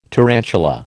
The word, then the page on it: tarantula: American English